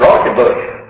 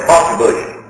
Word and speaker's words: Rauschenbusch: The American pronunciation of it is Rauschenbusch, but you don't pronounce it like the Germans pronounce it. He was German, origin Rauschenbusch.